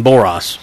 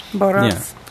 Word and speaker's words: Borås: The A is pronounced like the A in all. Oh, in Borås. – Borås.